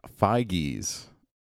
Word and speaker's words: Feige: It's Kevin Feige's. – Did you look it up? – Not Foggy, but Feige.